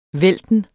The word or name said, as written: vælten